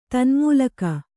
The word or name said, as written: ತನ್ಮೂಲಕ